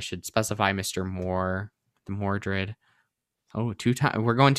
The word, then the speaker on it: Mordred: And so, with Merlin's help, aka Miles, we have Allie successfully, aka King slash Queen Arthur, defeating the evil Mordred.